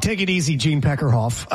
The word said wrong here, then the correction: Deckerhoff: Am I saying his name right? – Gene Deckerhoff.